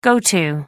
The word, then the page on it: go to: American English